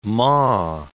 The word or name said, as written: maw